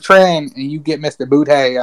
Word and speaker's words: Boutte: He's a player that I feel like a lot of people know, but he's the LSU receiver, Keyshawn Boutte. Okay, Boutte. – Say his name again for everybody listening. Keyshawn Boutte.